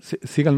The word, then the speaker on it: Siegel: Sí, hablaba Ethan Siegel, no, Siegel, siempre lo digo mal, porque creo que me traiciona el actor de las películas de acción.